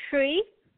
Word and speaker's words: Shree: It's Shree. – Shree. – Shree. – Spell it, please. – S-H-R-E-E.